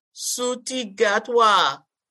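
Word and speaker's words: Ncuti Gatwa: Suti Gatwa.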